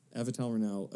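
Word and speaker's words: Avital Ronell: It came out that, am I going to say her name right? Avital Renau. – Yeah. – Avital Renau. Okay.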